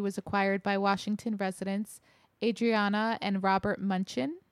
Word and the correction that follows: Mnuchin: Am I saying this right? – Munchin? I think it's Mnuchin.